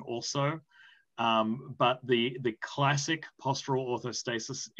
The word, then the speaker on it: orthostasis: Anyone checked the tilt table or postural orthostatus as a marker? – Yep, postural orthostasis, yes.